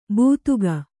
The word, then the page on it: ಬೂತುಗ: būtuga